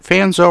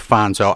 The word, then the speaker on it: Fanzo: Is it Fanzo or Fonzo? – Either. Italian Americans would call it Fonzo. All right. – Well, we'll go with Fonzo.